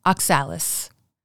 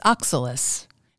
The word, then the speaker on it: oxalis: "That rampant plant which is the bane of my spring garden and of our whole town, I've always heard called oxalis." Yet the correct pronunciation is oxalis. Nearly every word in Webster's 3,210-page unabridged dictionary that begins with ox has the accent on the first syllable.